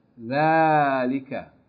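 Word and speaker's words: ذلك: ذَلِكَ الْكِتَابُ لَا رَيْبَ فِيهِ ذَلِكَ ذَلِكَ is written like this, but actually it's pronounced as though it's فَتَّ أَلِف. ذَلِكَ.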